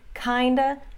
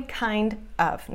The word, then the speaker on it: kind of: Então, acho que deu para perceber que em todas essas situações você viu kinda, sorta e outa usado. E a pronúncia não é kind of. Não tem pausa. E nem fala of v, no final. Kinda, sorta, outa.